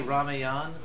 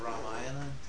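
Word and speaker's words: Ramayana (रामायण): Some devotees say Ramayan. – Yeah. – Instead of Ramayana.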